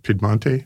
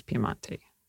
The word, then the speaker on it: Piemonte: And if you're, yes, that's the English pronunciation of Piemonte. The Italian is Piemonte.